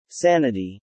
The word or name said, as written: sanity